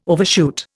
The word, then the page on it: overshoot: Indian